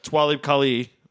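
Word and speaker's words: Talib Kweli: and then it'll get like to Twalib Kali.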